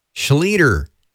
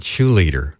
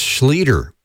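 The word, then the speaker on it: Schlueter: And before that, Seth Schleter, not Shoeleader, like I said. You know, I have a mental thing with his last name for some reason. And it's S-C-H-L-U-E-T-E-R, but it's just Schleter, rhymes with eater, you know, pizza eater, you know, or breakfast eater.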